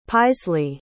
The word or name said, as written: paislee